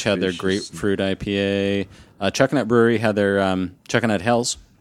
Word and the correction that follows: Helles: Yeah, the Helles. – Helles? – Helles.